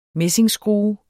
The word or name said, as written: messingskrue